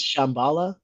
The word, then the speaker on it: Shambhala: It's actually pronounced Shambhala. – Oh. – Fuck you. – Just saying. It's actually how you pronounce it. It's not a sham.